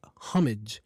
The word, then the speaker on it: homage: Also, the word homage, for example, H-O-M-A-G-E, is pronounced. That H is sometimes pronounced, we say homage. We can say the word homage. So it has two pronunciations.